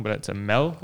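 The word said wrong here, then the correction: Meel: I think. – Meal. – M-E-E-L.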